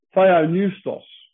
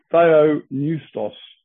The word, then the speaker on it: Theopneustos: Theopneustos is how you actually pronounce it. It's written down there for you. T-H-E-O. English people generally say Theo. It's Theo. Theopneustos.